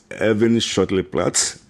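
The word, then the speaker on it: Erwin-Schoettle-Platz: Erwin-Schottenplatz, that's how we pronounce it, Erwin. But German, they pronounce the W-V. So it is Erwin-Schottenplatz here in the Stuttgart South.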